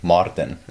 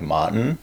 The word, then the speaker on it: Maartin: So one of the, like when you actually pronounce my name, like, so in South Africa, like our R's are really strong. – Yeah. – So when I'd introduce myself, it's like Maartin. – Yeah. – So that's how we say it.